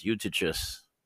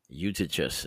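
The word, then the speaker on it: Eutyches: It's Eutychus. Eutychus is how you pronounce it.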